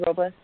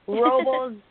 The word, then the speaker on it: Robles: I'm going to be out in Idlewild, and then I think I go to Paso, and then Paso Robles. Robles, dang it, I always say that wrong. Robles, and then San Diego, and then L.A.